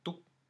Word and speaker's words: Touk: Took. T-O-U-K.